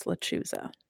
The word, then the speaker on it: Lechuza: I believe it's Lechuza.